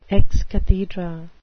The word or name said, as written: ex cathedra